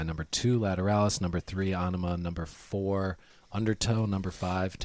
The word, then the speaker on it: Anima: Three, Anima.